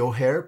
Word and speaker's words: O'Hare: Alors, euh, je ne sais toujours pas prononcer mon nom, donc je vais le prononcer à la française. Je m'appelle David O'Hare, ça se dit O'Hare puisque c'est un prénom anglais.